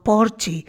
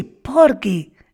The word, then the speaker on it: Porgy: Aunque todos la conocemos como Porgy and Bess, en la propia ópera, la pronunciación típica de la gente de color de aquella época lo decían así, Porky and Bess.